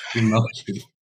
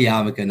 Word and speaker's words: yarmulke: Yarmulke. It's definitely yarmulke, not yarmulke.